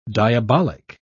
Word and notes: diabolic: British English

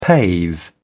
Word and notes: pave: British English

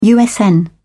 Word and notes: usn: British English

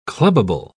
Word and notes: clubbable: British English

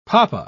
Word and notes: poppa: British English

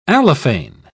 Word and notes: allophane: British English

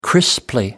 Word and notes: crisply: British English

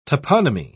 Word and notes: toponomy: British English

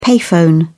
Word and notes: payphone: British English

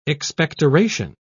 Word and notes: expectoration: British English

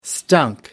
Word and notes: stunk: American English

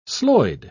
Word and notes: sloid: British English

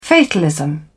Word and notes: fatalism: British English

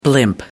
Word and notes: blimp: American English

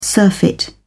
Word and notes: surfeit: British English

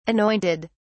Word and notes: anointed: British English

